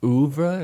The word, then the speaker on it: oeuvre: That's a very good song, but the rest of her oeuvre, is that how you say it? – Yes.